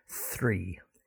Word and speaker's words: three: No, we're going to talk specifically about expressions with the word tree, T-R-E-E. Okay, now this is not the number three. Some people mispronounce this and say tree, tree men, tree apples, but three.